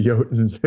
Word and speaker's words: jotun: In fact, there's a famous Norse myth where Thor goes fishing and goes fishing with a jotun. You know what a jotun is? Like a giant. A frost giant. It's spelled with a J, but they pronounce their J's like a Y in Scandinavia. So J-O-T-U-N.